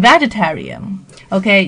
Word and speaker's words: vegetarian: 首先，它的发音呢，重音是靠后的，vegetarian，vegetarian，在这个 tarian 这边不要念成 vegetarian，OK，、因为我们知道蔬菜叫 vegetable 嘛，不要搞混了哈。